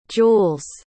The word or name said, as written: juelz